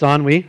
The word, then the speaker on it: ennui: So the word that he used was ennui. It looks like ennui. It's ennui. That's how you say it.